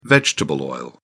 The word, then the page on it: vegetable oil: British English